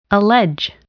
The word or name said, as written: allege